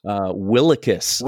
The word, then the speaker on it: Willekes: Is that how you pronounce it? Willekes. – Willekes. – Willekes. I'm sorry. – Pronunciation according to Dane Brugler.